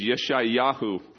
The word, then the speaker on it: Yeshayahu: Remember, I told you Isaiah's name is Yeshayahu in Hebrew.